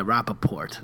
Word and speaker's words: Rappaport: Yeah, Rappaport. That's how he pronounced his last name.